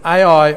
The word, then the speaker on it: Ai: And then in verse 8, he goes and moves to a place in the hills with Bethel on the west and Ai on the east. That's how I pronounce Ai.